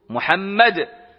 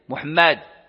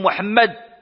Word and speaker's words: محمد: ومحمد أبلغ من محمود، محمد هكذا أي اسم مفعول محمد، لذلك البرابر هم الذين ينطقون أو ينطقون بها صحيحة، وخا بعضهم تزيد الألف محمد هذا خطأ، وإنما هما اللي هما يقولوا محمد مشددة اسم مفعول من يعني الحمد.